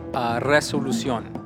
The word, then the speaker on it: Resolución: It's just a pronunciation. Resolución. – Yeah. – All right. – That's right. Gotcha.